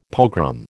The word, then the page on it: pogrom: American English